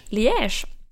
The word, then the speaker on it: Liège: Jag har ju hittat en studie från det belgiska universitetet Liège, kanske det uttalas, som har studerat vittnesmål. – Liège.